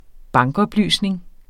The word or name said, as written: bankoplysninger